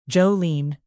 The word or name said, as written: jolene